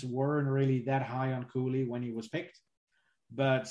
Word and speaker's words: Cooley: It's pronounced Cooley. It's a difficult name. When you look at it, it can be difficult to figure out how to pronounce it.